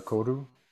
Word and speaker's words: koru: The concept of koru, is that how you say it? – Yeah, the koru. Yeah.